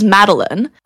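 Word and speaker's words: Madeleine: Yeah, so my full name is Madeline, spelt with 3 E's, so it's M-A-D-E-L-E-I-N-E,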